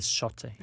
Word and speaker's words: Sotte: Og jeg har, øh, jeg, jeg har lovet på opfordring at lave et lille bitte udtalekursus. Han bliver bare kaldt Sotte.